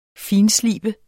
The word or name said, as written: finslibe